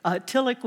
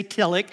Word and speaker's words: Tillich: So Tillich was pronounced Tillich, and we finally got him to say Tillich.